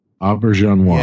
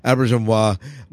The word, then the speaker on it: Auberjonois: Auberjonois. – Yeah, I can never pronounce it. Auberjonois.